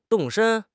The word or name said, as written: 动身